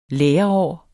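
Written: LÆREÅR

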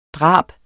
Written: DRABENES

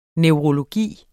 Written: neurologi

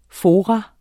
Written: FORAENES

